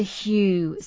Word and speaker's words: Hu: We're going to pronounce this in the traditional way, such as Hu.